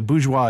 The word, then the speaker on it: bourgeois: If you're going to say it appropriately, I didn't quite nail it because you need to almost choke at the end. Bourgeois.